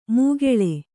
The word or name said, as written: ಮೂಗೆಳೆ